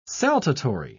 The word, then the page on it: saltatory: British English